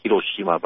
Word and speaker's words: Hiroshima: And it's pronounced Hiroshima, by the way. – Hiroshima. – Hiroshima, you're right.